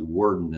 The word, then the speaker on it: Worden: I keep saying Worden. It's actually Worden is the way, correct pronunciation, I'm told.